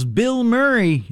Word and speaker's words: Bilmuri: They call themselves Bill Murray. It's spelled B-I-L-M-U-R-I.